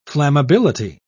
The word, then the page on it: flammability: British English